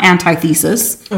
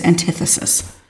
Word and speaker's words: antithesis: I did look up the pronunciation. It's not antithesis. – Okay. – It is antithesis.